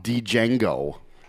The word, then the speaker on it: Django: Django. – I think the D is unpronounced, though. – Django.